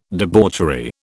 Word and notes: debauchery: British English

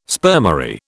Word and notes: spermary: British English

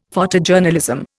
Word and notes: Photojournalism: Indian

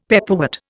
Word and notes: pepperwort: Indian

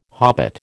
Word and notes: hobbit: American English

